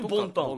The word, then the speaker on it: 文旦: ボンタンは、漢字で書くと文章の文に、一旦の単、うん。元旦とかの単。 – ああ、はいはいはいはい。 – で、ボンタン。 – ボンタン。 – 文旦と書いて。